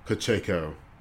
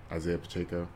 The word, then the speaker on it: Pacheco: total rushing yards from Pacheco. That's how you say his name, right? Isaiah Pacheco. Yeah.